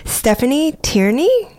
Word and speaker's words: Stephanie Tierney: Stephanie Tierney, did I say that right? – That's correct.